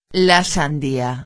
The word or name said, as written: la sandia